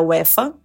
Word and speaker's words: UEFA: A gente veio pra cá porque vamos trabalhar os dois na Euro, que é o campeonato de futebol da UEFA.